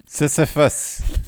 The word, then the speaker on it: Sisyphus: So in the Myth of Sisyphus, I can't say the name right. – Sisyphus.